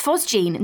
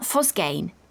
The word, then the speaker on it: phosgene: And it's pronounced phosgene, not phosgane.